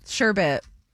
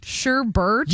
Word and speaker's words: sherbet: Sherbet. There's no R.